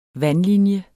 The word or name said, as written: vandlinje